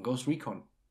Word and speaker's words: Ghost Recon: Ghost Recon.